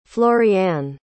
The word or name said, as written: floriane